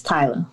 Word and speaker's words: Tyla: Did I pronounce it right? Is it Tyla? – Yes, Tyla.